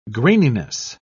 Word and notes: graininess: British English

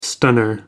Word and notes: stunner: American English

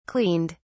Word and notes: cleaned: British English

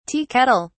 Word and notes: tea kettle: British English

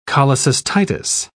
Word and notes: cholecystitis: British English

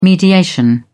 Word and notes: mediation: British English

